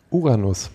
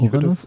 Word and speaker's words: Uranus: Betonung liegt auf dem A. Was? Uran. Uranus? – Uranus mit Doppel-S. – Uranus. – Hm. – Okay, klingt wie Anus.